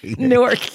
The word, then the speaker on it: Newark: Newark. And you have to say it like that.